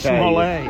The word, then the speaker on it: Smollett: It's actually pronounced Smollett. – Yeah. Journey Smollett.